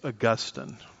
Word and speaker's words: Augustine: Augustine, and actually that's not even the right way to pronounce that. I think it's Augustine, okay? It depends on which syllable gets the emphasis, as we like to say.